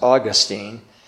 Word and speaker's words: Augustine: Augustine, the early church father, sometimes pronounced Augustine, but if you want to know the difference, I had a professor in seminary, and he wasn't the only one who said this, but St. Augustine is in Florida, Augustine is in heaven, so that'll help you with the pronunciation of this man of faith.